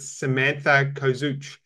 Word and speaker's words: Samantha Kozuch: is uh, a lady by the names of Samantha Kozuch.